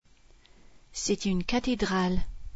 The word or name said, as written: cathédrale